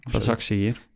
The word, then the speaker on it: Başakşehir: Başakşehir. – Başakşehir. – Ja, dank je. – Precies zoals de letters op volgorde staan.